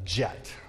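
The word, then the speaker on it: kyat: This is roughly, this is a jet, is how you pronounce it.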